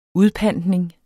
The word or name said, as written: udpantning